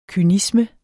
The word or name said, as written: kynisme